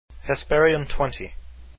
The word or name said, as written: hesperionXX